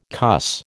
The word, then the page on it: cuss: American English